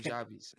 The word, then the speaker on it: Nujabes: Nujabes. Okay. Nujabes. His name's Nujabes. I believe it's spelled N-U-J-A-B-E-S.